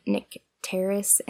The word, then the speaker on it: Nycteris: And the Audible guy, there were a lot of different ways, but the Audible guy said Nycteris, and so that's what I am going to attempt to keep it as. Okay, Chapter 5, Nycteris.